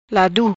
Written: Ladu

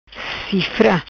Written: Cifra

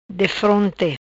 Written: Defronte

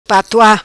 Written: Patua

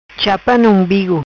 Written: Chapa Na Umbigo

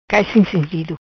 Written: Cai sin sintido

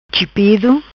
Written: Chipido

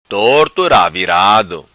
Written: Torto Ravirado

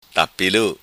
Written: Tapilu

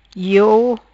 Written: Iou

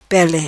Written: Pele

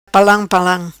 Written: Palam Palam

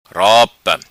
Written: Ropa

